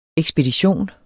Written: ekspedition

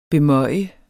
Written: bemøje

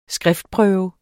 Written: skriftprøve

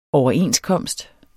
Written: overenskomst

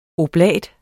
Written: oblat

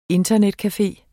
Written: INTERNETCAFE

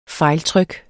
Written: fejltryk

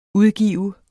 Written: udgive

